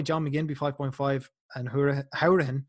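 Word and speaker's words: Hourihane: I actually realize I've already pronounced his name wrong. He likes to be pronounced uh, Howrahan, not Hurahan.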